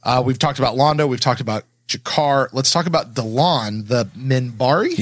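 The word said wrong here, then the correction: Delenn: Delen, the Minbari, I think. – Yeah, there it is. – Yeah. – Delen, the Minbari.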